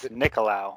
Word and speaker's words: Nicolau: It's actually Greek. It's Nicolau.